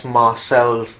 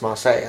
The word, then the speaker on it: Marseille: And someone says, you know, it's Marcel's there, because that's how it's spelled. They don't know that it's meant to be pronounced Marseille.